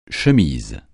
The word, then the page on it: chemise: français